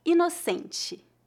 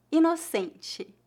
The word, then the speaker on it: inocente: Note que a palavra inocente em português é escrita com apenas um N e adicionamos a letra E ao final como a maioria das palavras em português, a sílaba tônica dessa palavra é a penúltima, inocente. Observe que as letras TE são pronunciadas como ti, ti, em final de palavras.